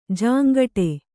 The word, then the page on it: ಜುಂಗುಟ್ಟು: jumguṭṭu